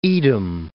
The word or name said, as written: Edom